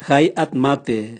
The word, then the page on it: gai atmate: Raga